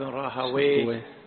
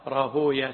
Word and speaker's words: راهويه: قلنا كل اسم ينتهي بواو وياء وهاء فاللغويون يقولون ويه، إسحاق بن راهويه، نفطويه، سيبويه، شبويه. أما المحدثون فيقولون لا، يكره نطق الاسم بويه. وذكروا أن ويه وصف النوصاف أو اسم من أسماء إبليس بالفارسية، وإنما يقال راهويا سيبويا شبويا.